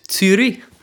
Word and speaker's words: Zürich: Yeah, and the Swiss people they'd call it Zürich.